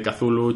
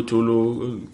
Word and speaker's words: Cthulhu: Entonces me he puesto ahí con Howard Philip, que ya pues en el instituto, sobre todo, jugando a la llamada de Cthulhu, Chulu, Cthulhu, cada uno como lo pronuncia. – Cthulhu digo yo. Cthulhu, yo digo Cthulhu.